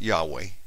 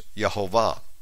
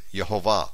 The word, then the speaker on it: Yehovah: And it wasn't Yahweh, as some had speculated. It was Yehovah. That's how you pronounce it, Yehovah.